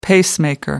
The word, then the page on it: pacemaker: American English